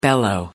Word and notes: bellow: American English

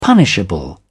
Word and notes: punishable: British English

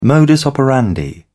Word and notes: modus operandi: British English

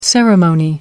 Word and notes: ceremonial: British English

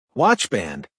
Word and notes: watchband: American English

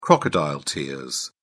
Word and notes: crocodile tears: British English

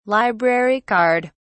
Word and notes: library card: British English

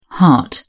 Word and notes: hart: British English

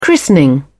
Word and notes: christening: British English

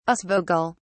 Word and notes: aasvogel: British English

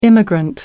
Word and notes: immigrant: American English

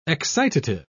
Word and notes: excitative: British English

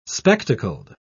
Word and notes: spectacled: British English

